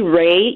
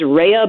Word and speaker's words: Rhea: It's actually Ray. You're saying it correctly. It's an Italian last name, so it truly is Rhea, but we're Americanized, and it's Ray.